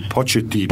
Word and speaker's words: Pochettino: Come on, you say it with me, Pochettino. – Pochettino, was that right? There you go.